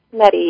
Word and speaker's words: Nettie: Nettie, yes. It's like Betty with an N, Nettie.